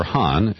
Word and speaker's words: Khan: Khan, or Han, as it's pronounced.